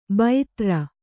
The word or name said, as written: ಭೈತ್ರ